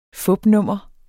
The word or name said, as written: fupnummer